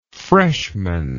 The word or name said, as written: freshmanship